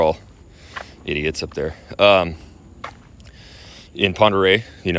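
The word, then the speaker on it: Pend Oreille: Ponderé is our lake, and it's spelled P-E-N-D-O apostrophe O-R-I-E-L-L-E.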